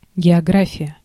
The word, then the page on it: география: /ɡʲɪɐˈɡrafʲɪjə/; /gʲɪɐˈgrafʲɪjə/